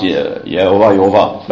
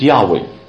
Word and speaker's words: Yahweh: You pronounce Yehovah, but it's Yahweh.